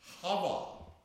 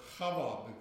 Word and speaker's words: Chava: Now the word Eve in the original Hebrew is Hava, or Chava, because the word begins with a he. It's a guttural and expirational word. A word you have to breathe out to pronounce it.